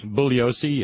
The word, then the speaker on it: Bugliosi: But there was another, how do you pronounce his name? Bugliosi? – Bugliosi, yes.